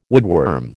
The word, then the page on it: woodworm: American English